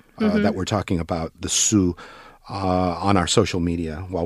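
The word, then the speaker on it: su (수): This Chinese character is pronounced as su.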